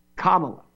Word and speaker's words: Kamala: Now, she wants you to pronounce her name Kamala. Accent on the first syllable.